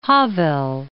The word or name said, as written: Havel